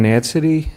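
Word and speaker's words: Nadsady: My name is Ken Natsity.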